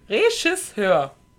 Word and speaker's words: Regisseur: Regisseur. – Dann versuch's doch mal so. – Das Reh scheißt irgendwo hin. Reh schiss, hör.